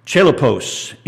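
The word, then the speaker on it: chalepos: It's the word, we pronounce it chelopos in English, but it means difficult, harsh, fierce, and violent.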